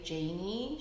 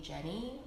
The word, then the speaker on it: Janie: Yeah, it's so funny because my mom can't even say my name. Like she can't say Janie. She calls me Jenny or Janie or any form of that, um, uh-huh.